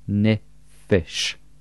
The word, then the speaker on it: Nefesh: Wir haben also hier die drei Silben von Nefesh, Ne, Fe und Sh. Im Hebräischen wird dann zwischendrin jeweils ein kleines E eingesetzt, Nefesh.